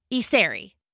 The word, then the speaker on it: Iseri: My last name is Isery. It's spelled I-S-E-R-I. – Isery. Isery? – Isery. – Isery.